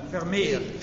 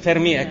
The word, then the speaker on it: Vermeer: ma è giusto la pronuncia? – Vermeer. – Vermeer, ecco, questa è la pronuncia esatta.